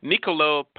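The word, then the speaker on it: Niccolò: Niccolo Paganini, Violin Concerto Number 1, Opus 6, Allegro Maestoso? – Maestoso, right.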